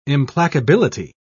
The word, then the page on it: implacability: British English